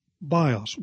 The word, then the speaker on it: bios: The Greek noun used in this phrase is pronounced bios.